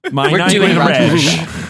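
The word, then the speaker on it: Michell: That's not how you say it. It is how you say it. Roger Michelle. You said it right.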